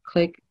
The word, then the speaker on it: QLIC: This particular episode is part of the series Click by BRQ. Click stands for, is QLIC, and that is pronounced click, like I said.